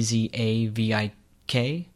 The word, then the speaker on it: Dzavik: Overgaard and Vladimir Zavik, which is spelled D Z A V I K, Zavik.